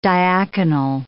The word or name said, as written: diaconal